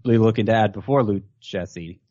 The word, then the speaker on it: Lucchesi: Are you interested in adding Joey Lucchese? I hope that's how you pronounce it. I forgot to look that Lucchese? – L-U-C-C-H-E-S-I.